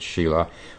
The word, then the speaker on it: sila: The second is Shila, it's spelled S-I-L-A, they pronounce it Shila, which means morality or upright conduct.